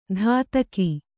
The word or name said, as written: ಧಾತಕಿ